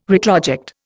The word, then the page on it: retroject: Indian